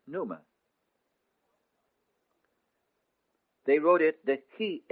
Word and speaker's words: pneuma: The word for spirit in the Greek language is pneuma, pronounced pneuma. The word for air in Greek is also pneuma.